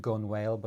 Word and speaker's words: gunwale: Right, and the gunwale of a boat, which is written gunwale, but it's pronounced gunwale.